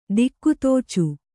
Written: ದಿಕ್ಕುತೋಚು

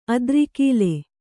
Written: ಅದ್ರಿಕೀಲೆ

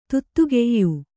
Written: ದಾಧಿಕ್ಯ

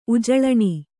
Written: ಉಜಳಣಿ

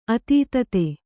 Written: ಅತೀತತೆ

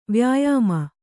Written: ವ್ಯಾಯಾಮ

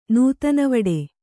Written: ನೂತನವಡೆ

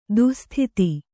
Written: ದುಃಸ್ಥಿತಿ

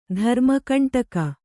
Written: ಧರ್ಮಕಂಟಕ

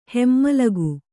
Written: ಹೆಮ್ಮಲಗು

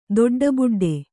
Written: ದೊಡ್ಡಬುಡ್ಡೆ

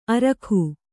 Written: ಅರೋಗ

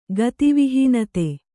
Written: ಗತಿವಿಹೀನತೆ

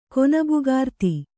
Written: ಕೊನಬುಗಾರ್ತಿ

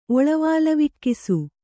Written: ಒಳವಾಲವಿಕ್ಕಿಸು

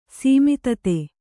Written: ಸೀಮಿತತೆ

